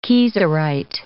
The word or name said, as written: kieserite